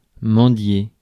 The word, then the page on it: mendier: France; /mɑ̃.dje/